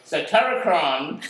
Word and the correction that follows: Satyricon: won't release a new album until next year, 2017. – Satiricon. – Satiricon.